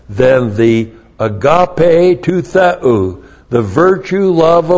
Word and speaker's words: agape: and that is agape, A-G-A-P, long E.